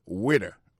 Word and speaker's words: waiter: Alors moi, je prononce le waiter. Waiter, ça veut dire accent tombé sous premier syllabe là. Waiter, W-A-I-T-E-R.